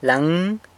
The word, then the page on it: laëng: sanlucasquiavini